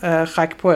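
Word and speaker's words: Gakpo: من یه بازیکن بخوام بگم تو اینا که قیمتشون رفته بالا، چون هلندم نگفتیم، به خاکپو دیگه، دقیقاً فکر کنم تلفظش گاکپو میگن انگلیسی. – نمیدونم، من گاکپو میگم بهش. – گاکپو ولی انگلیسی گاکپو میگن، ولی فکر کنم هلندی همون خاکپو همچین چیزیه.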